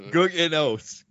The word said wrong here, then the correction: Gugino: Guggenos. Ryan, take a run at it. – Gugino? – That's better.